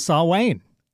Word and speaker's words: Samhain: Because of Samhain. – Yeah. – Which is spelled Samhain, I think. – Yep. – Yeah. – And that's exactly what it is. So you're saying it right.